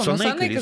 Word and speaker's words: Санейка: Санейка, вот все время с ударением, меня все время белорусы поправляют, потому что это белорусский сорт, замечательный белорусский сорт. – Надо «Санейка»? – Все время забыла. Санэйка звучит красиво.